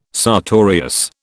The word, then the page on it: sartorius: British English